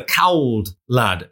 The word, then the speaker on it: Cauld: You were calling it the Cold Lad there, but I mean, to be real proper Geordies, we should pronounce this as the Cold Lad, shouldn't we? – The Cold Lad of Hilton. – Yeah, the Cold Lad, and they spell it C-A-U-L-D, but it's basically this story of this lad, this stable boy called Robert Skelton.